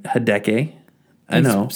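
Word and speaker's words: Hideaki Anno: Hideki Anno? – Hideki Anno. – Spell that. – H-I-D-E-A-K-I.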